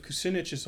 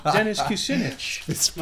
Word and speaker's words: Kucinich: It reminds me of Kucinich's old ads. Rhymes with spinach. Dennis Kucinich.